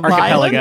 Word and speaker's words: archipelago: It's like it's an archipelago? Is that how you pronounce it? Series of archipelago. – Islands? Archipelago. There we go.